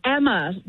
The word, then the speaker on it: Emma: Daughter, it's Emma. Yeah, pronounced like hammer, but no H.